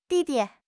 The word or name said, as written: di4 di5